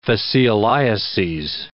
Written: fascioliases

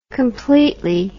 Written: completely

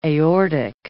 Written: aortic

